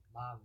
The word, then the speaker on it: Malin: You said Mylan? Mylan. She's like a, a Persian. Persian Swedish. – That's how you say it too? Mylan, yeah. See. M A L I N.